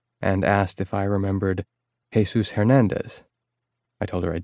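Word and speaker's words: Jesús: That was quite a while ago, but it's hard to forget a 6'6" detective named Jesus, even if it is pronounced Jesus.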